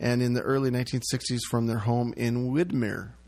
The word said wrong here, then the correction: Windermere: Windermere. You know, the listening audience, Steph, doesn't know that it's pronounced Windermere.